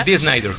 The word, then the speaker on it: Dee Snider: Dee Snider.